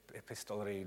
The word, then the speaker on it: epistolary: You know, I mean, it would be possible you could go into a very sort of contrived way of, making them all epistolary novels.